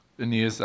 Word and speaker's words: Aeneas: Yeah, you know, I really liked the part that you brought out from the text yesterday about the healing of how do you say the first guy's name? – Aeneas. – Aeneas? Thanks.